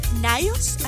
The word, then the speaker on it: Nyos: Es por aquí, fíjate, es Lago Nyos, así se pronuncia.